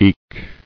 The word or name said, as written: Eked